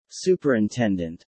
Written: Superintendent

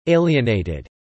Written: alienated

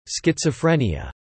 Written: schizophrenia

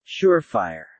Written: surefire

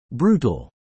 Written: brutal

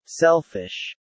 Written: selfish

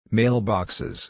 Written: mailboxes

